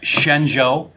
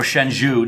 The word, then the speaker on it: Shenzhou: We've got the USS Shenzhou. Or Shenzhou, depending on if you want to say it wrong.